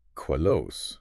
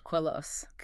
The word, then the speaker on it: Queloz: And just a quick note for everyone listening on that second name, it's spelled Queloz, but the Z is silent. It rhymes with hello. – Queloz. – Queloz. – Got it. Queloz like hello.